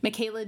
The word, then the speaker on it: Michela: Michaela D. London, that's spelled M-I-C-H-E-L-A, D like David, London.